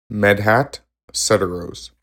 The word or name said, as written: Medhat Sedarose